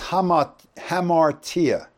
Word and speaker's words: hamartia: The first one is hamartia, H-A-M-A-R-T-I-A. It's really A-M, but they put the H on the front of it in Greek when they put it in English because that's how it's pronounced, okay?